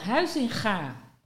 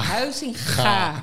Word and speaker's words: Huizinga: and when you're not Dutch, you don't know how to pronounce this word, I'm sure, because it always makes people nervous. Johan Huizinga. – There we go. – With the ga. – Huizinga. Yes. Exactly.